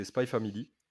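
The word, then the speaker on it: Spy×Family: Qui a écrit Spy Family Alors, Spy Family, donc apparemment on ne prononce pas le X, euh, donc c'est Spy Family.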